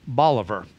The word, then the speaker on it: Bolivar: It's like when I lived in Missouri, a town that was like Simon Bolivar was pronounced Bolivar.